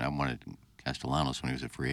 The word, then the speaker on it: Castellanos: I like the silent L's. Castellanos. – Castellano. Well, the you double L in Spanish Olavable becomes a Y.